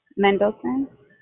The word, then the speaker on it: Mendelson: Mendelson.